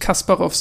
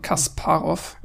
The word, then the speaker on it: Kasparov: Es äh, spricht sich Kasparov aus. – Nicht Kasparov, sondern Kasparov.